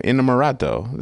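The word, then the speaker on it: Innamorato: While Costa had previously backed restrictive bills around immigration and reproductive rights, Inamorato, I swear I'm saying that wrong, Inamorato, that's it, voiced support for a swath of progressive policies around these and other issues critical to Pittsburgh area voters.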